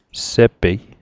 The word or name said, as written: sepei